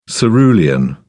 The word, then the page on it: cerulean: British English